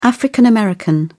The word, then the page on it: african-american: British English